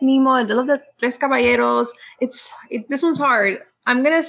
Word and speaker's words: Caballeros: I feel like is the only one that could really pronunciate the Caballeros. Because we all say Caballeros. Say it again. – now she's all nervous. – The Tres Caballeros. – That's how you're supposed to say it.